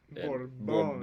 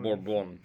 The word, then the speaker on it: Bourbon: But at the same time, the French pronunciation was just perfect. Bourbon. Bourbon.